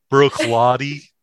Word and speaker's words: Bruichladdich: Bruichladdich. – Yes, I pronounced it correctly. Bruichladdich.